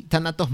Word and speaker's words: Tánatos: Y bueno, un último comentario acá de Laura Escobar que dice Tánatos, ya vi que si se pronuncia así, tanatos muerte.